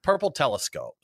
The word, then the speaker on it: Purple Telescope: Well, right now, we're sipping some Purple Telescopy, I think is the way you pronounce it. – Telescopy, right? – No. Yeah. – Purple Telescope. So we're drinking Purple Telescope, Berliner with the fruits, raspberry, blackberry, and strawberry.